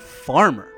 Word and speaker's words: Farmar: I went to high school with the dude, and back then, he went with the pronunciation farmer.